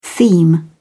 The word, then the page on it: theme: British English